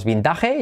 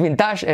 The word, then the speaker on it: vintage: No es vintage, es vintage. Como, vente pa' aquí, vente, vente, vente. Con V, V. Vintage, vintage.